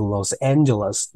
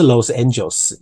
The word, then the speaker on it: Los Angeles: Howard said of returning to Los Angeles this season。 Howard said of，你看哦，of 后面是不是介系词后面的动作直接加 ing of doing something，returning 回去 to，呃，洛杉矶讲一下它的发音哦，Los Angeles，Los Angeles 不是 Los Angeles，是 Los Angeles this season。